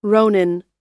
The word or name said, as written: ronin